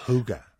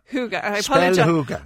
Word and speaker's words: hygge: Hygge. – Hygge. I apologise to all Danish people if I'm mispronouncing that. It's spelt H-Y-G-G-E. Hygge.